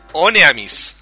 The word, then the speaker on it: Honnêamise: Y, para la gente que no lo sabe, se dice The Wings of Oneamis. La H no se pronuncia.